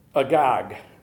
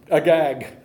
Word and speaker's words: Agag: Because there is a character, I want to make sure I say his name right, that we're going to meet next week, who is Amech. Let me see here. Agag. Really, that's it. Agag. That's his name.